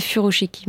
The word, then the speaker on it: furoshiki: On a fait un article sur Reporter récemment, il y a un podcast aussi, comment faire le fukukoshi, c'est-à-dire des emballages. Je prononce mal Je crois que c'est furoshiki, mais bon. – ah, furoshiki, voilà, pardon.